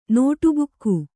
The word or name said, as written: ನೋಟುಬುಕ್ಕು